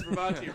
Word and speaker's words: Bravazzi: It says Bravazzi rhymes with Yahtzee. – That's a good way to remember it. Yahtzee, Nazi, Bravazzi.